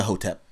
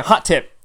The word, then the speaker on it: hotep: You know, I didn't even pronounce it that way in my head. – What did you think it said? – It was like a hot tip.